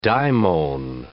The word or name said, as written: daimon